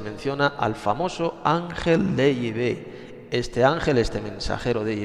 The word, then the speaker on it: Yahvé: Yahvé es la verdadera, la auténtica pronunciación del Dios de Israel. No es ni Jehová, Jehová es un nombre más falso que Judas, ese no es el verdadero nombre de Dios.